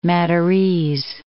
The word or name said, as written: Madurese